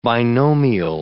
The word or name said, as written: binomial